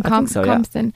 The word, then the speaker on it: Compston: Is that how you pronounce the second name? – Compston. Yeah.